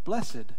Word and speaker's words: blessed: And you look up the word blessed, B-L-E-S-S-E-D, it will say the adjective form of that word is pronounced blessed, B-L-E-S-S-E-D. And I go, okay, this is not a South Arkansas Southern fried, bona fide, chicken fried word. This is actually how it's supposed to be pronounced. So when we read through these, it's blessed because it's an adjective.